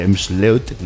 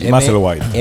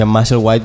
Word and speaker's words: Maslewhite: Y entonces ahí llegó el formato digital y llegó la primera referencia, que la gestionó Raúl completamente, de Emsleut, no sé si lo digo bien. – Maslewhite. – M-S-L-W-T. Pero luego nos enteramos que se pronuncia Maslewhite porque es el apellido del artista.